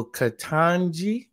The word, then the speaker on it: Ketanji: So Ketanji, am I pronouncing it correctly? – Yep.